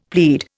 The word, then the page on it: plead: Indian